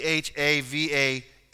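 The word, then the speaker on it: Hava (חוה): Her name in Hebrew is it's spelled Chava, C H A V A H or V U H, however you want to say it, but it's Hava.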